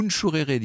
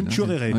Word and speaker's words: Nchourere: Nchourere, dit-on, paraît-il. – Nchourere. – Oui, c'est comme ça que ça se prononce.